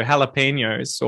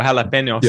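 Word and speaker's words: jalapeños: it's so funny how you get used to saying it with the Spanish pronunciation. So jalapenos or jalapenos. Yeah. I think would be the way you would say it.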